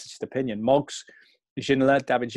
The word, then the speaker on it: Ginola: He's spelled G-E-N-O-L-A, but obviously it's supposed to be G-I-N-O-L-A. Ginola, we called him for a long time. Then Ginola, I think, is the French pronunciation.